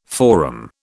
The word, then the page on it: forum: British English